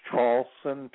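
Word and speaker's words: Traulsen: My name is spelled Hugh, H-U-G-H. The last name is Charlson, T like Tom, R-A-U-L-S-E-N like Nancy.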